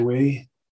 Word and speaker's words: oui: And not like the French way of saying yes. You know, they say we. – Yes, I think it's O-U-I spelled. – Well, O-U-I, and I say me.